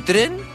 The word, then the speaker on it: Citroën: ¿Sabe usted que dice Citroën? Esa, así se pronuncia. Pero solo lo conocemos acá como Citroën. Por lo tanto, por eso lo decimos así.